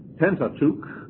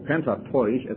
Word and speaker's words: Pentateuch: And of course these five books are called Pentateuch, or Pentateuch as it should be pronounced, but I better be careful when I talk about the right pronunciations.